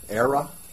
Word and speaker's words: Aira: Aira. It's spelled A I R A. So it's the word air with an A on the back.